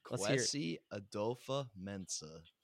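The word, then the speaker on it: Kwesi Adofo-Mensah: Kwesi Adofa-Mensah.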